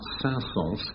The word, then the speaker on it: Saint-Saëns: The famous composer Saint-Saëns, I'm assured he is pronounced Saint-Saëns, composed a light-hearted piece called Carnival of the Animals.